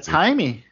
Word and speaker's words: Jaime: Jaime. That's right. Yeah. Jaime is the Latino pronunciation.